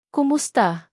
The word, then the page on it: kumusta: Tagalog